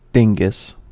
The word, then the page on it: dingus: American English